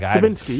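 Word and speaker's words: Fabiński: Did I say his name right? Bartosz Fibinski. Yeah, that's right.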